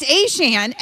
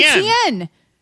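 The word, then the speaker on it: Achane: And so apparently, A-Chain, who was the artist formerly known as ETN, says that it's always actually been A-Chain, but we've always been pronouncing it wrong with ETN.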